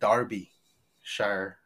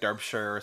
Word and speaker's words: Derbyshire: But yeah, so Delia Derbyshire, you know, another funny thing, her last name is spelled like Derby, you know, like roller derby, but in the documentaries I watched, they always pronounce it Derbyshire.